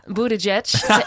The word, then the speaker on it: Buttigieg: Ja, Buttigieg.